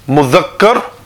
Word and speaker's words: mudhakkar: So to pronounce it is mudhakkar, and feminine mu'annath.